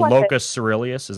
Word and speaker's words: locus coeruleus: And what's It's called the locus coeruleus.